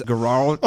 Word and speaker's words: Guaraldi: Honestly, Vince Guaraldi.